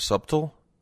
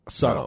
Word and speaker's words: subtle: For me, the effects were lovely and subtle. How do you say this word? Subtle? – Subtle. No. Subtle. – But so the B's silent, huh? – Yes, man.